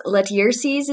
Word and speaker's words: Lityerses: I think it's Lityerses is how you pronounce it.